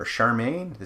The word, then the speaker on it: Charmian: Two other key members of the cast to talk about, for sure, would be Eleanor Parker and Charmian, or Charmaine. It's spelled C-H-A-R-M-I-A-N, Carr. So let's go with Charmian.